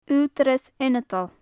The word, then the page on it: ughdarras ionadail: gaelic